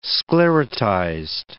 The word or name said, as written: sclerotized